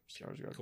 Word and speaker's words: Skarsgård: Is that how you say his name? Skarsgård. – Yeah, Skarsgård.